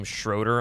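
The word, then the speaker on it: Schröder: he pronounced his name Schroeder on the radio the night, which really flew people for a loop because that's what the umlaut is for on the O over his name.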